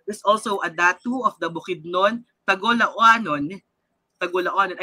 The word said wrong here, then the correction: Tagoloanon: I'm sorry, tama sana ang pronunciation ko. Tagoloanon.